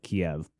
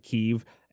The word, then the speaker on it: Kyiv: Of course, we in the West and Russia pronounces it as Kiev, but they pronounce it Kiev, and they spell it K-Y-I-V over there.